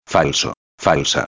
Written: falso-a

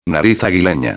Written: aguilena

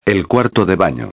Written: el cuarto de bano

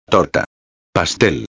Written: torta pastel

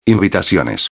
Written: invitaciones

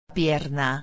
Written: pierna

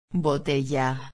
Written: botella

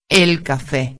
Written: el cafe

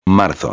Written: marzo

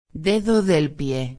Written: dedo del pie

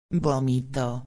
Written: vomito

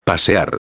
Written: pasear

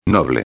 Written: noble

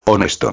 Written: honesto

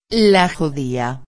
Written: la judia

